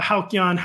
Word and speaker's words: Halcyon: You know, back in the Halcyon, is that how you pronounce the word millennial days when we were growing up? – I believe so.